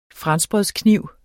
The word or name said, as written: franskbrødskniv